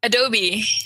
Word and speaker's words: Adobe: Is it Adobe or is it Adobe? – Adobe. – Okay, great. Adobe. Yeah. – But people in Paris call it Adobe. So. – Okay. – But people at the Adobe office call it Adobe.